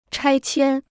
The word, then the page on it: 拆迁: chāi qiān